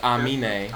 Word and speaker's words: Aminé: People kept calling him anime. He says, I never even watched anime. It's Aminé.